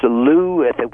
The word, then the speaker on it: Looe: It's Loo, which is spelled Looe, I believe.